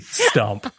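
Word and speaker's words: Stuhmp: And then I went, this is my name now, and it's S T U H M P Stump. – the H is silent, yeah.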